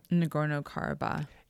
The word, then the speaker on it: Nagorno-Karabakh: Nagorno-Karabakh.